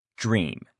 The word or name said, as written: dream